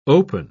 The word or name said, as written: open